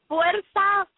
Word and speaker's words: Fuerza: Fuerza, Fuerza, Fuerza.